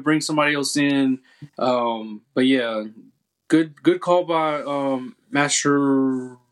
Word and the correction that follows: Mascherano: I think it's his name, Mascheroni. I'm not good with his name. – I don't want to butcher his name either. Mascherano, I think. There you go.